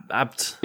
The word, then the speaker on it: Abt: Yeah, ABT. – How do you pronounce that? – Abt.